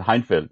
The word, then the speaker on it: Hejnfelt: Yeah, it is a funny name, but Martin Hejnfelt.